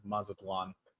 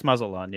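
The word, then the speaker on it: Mazatlán: or if Dallas or Mazatlan? I'm not saying that right. – Yeah. It's Mazatlan.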